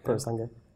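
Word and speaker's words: Purosangue: How do you say it, by the way? – Pure sangue. Is that right? Yeah, pure sangue.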